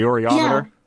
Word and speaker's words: Oreometer: The Oreometer? – Yeah. Yeah, so we started out using... – How I say it. – Yeah, you can pronounce it how you want. We call it the Oreometer.